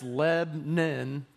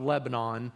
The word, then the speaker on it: Lebanon: It's Lebanon, two syllables, not Lebanon. That's the quickest way for someone to spot if you're not from Middle Tennessee, is how you pronounce that.